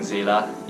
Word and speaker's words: Zayla: So before I go on to anything else, I'd like to thank Zayla, yes, I've confirmed that that's how you pronounce it, for doing up an arrangement of this as early as before the OST was released, I'm quite sure.